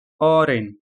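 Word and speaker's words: oren: كلمة برتقالي أورين أورين أورين، نفس كلمة أورانج، ولكن هنا ينطقون أورين أورين أورين، ممتاز.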